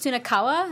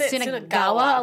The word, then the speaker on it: Sunakawa: His name is Sunakawa. His last name, but they say it's Sunagawa a lot, which is weird.